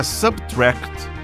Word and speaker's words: SBTRKT: A pronúncia do nome é Subtract, segundo o criador do projeto, o produtor nascido em Nairobi, Aaron Jerome.